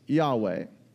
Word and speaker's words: Yahweh: But if you go into the Hebrew, the actual word there is spelled YHVH. We sometimes say it as Yahweh.